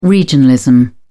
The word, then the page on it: regionalism: British English